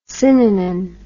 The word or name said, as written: synanon